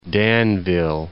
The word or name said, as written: Danville